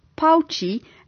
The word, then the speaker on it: Baoji: 'N Ander interessante uitspraak wat vir ons sal vreemd wees, is dat B's as P's uitgespreek moet word, so die pleknaam wat B-A-O-J-I gespel word, moet uitgespreek word as Pouchy, en daar hoor jy nou sommer ook dat die J as 'n sh uitgespreek moet word.